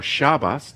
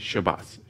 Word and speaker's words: Shabbos: The root of the Hebrew word Shabbat or Shabbos, depending if you how you pronounce it in Israel, it'd be Shabbos. But over here, you know, in more of an Ashkenazi pronunciation would Shabbat, right? But in Israel, over in the land, it's Shabbos, Shabbos, right?